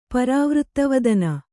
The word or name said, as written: ಪರಾವೃತ್ತವದನ